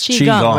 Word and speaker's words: Qigong: It's a lifestyle and it's a healing art called Qigong. Did I pronounce that correctly? – Yes, Qigong, right? – Qigong, yes.